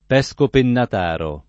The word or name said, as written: Pescopennataro